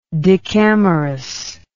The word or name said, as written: decamerous